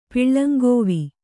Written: ಪಿಳ್ಳಂಗೋವಿ

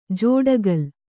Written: ಜೋಡಗಲ್

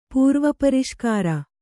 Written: ಪೂರ್ವಪರಿಷ್ಕಾರ

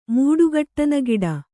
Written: ಮೂಡುಗಟ್ಟನಗಿಡ

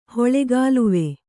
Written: ಹೊಳೆಗಾಲುವೆ

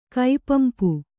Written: ಕೈಪಂಪು

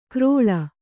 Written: ಕ್ರೋಳ

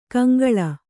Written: ಕೌಂಕುಳ್

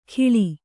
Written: ಖಿಳಿ

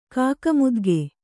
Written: ಕಾಕಮುದ್ಗೆ

